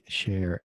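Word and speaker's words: Scher: But basically just take the S from Sunny, and then after that just put Share.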